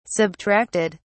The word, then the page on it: subtracted: British English